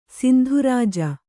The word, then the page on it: ಸಿಂಧುರಾಜ: kannada